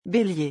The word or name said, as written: belier